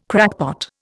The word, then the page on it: crackpot: Indian